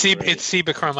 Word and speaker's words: Cibachrome: It's Cibachrome.